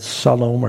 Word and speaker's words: salam: connected with the word salam or shalom. Salam is how you say it in Arabic.